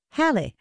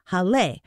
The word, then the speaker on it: Halle: And by the way, a quick note on the pronunciation of the word Halle. The accent is actually on the E, and so you would think you'd put the stress on it that way and say Halle. But the accent is only there to let English speakers know that this was a German person's last name and that it was to be broken into two syllables and not pronounced Hall or something. But it is pronounced with the stress on the first syllable, Halle.